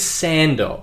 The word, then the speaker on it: Sandor: Is Sandor. – What is it? – Sand what? – Sandor. – Sandal? – Sandor. Spell it for me. Spell it for the folks at home. – S-A-N-D-O-R.